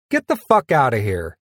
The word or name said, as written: get the fuck outta here